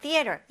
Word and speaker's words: theater: 자, 그러면 theater, 이 th 발음 좀 주의해서 한번 선생님과 발음해 보세요. theater, theater, theater.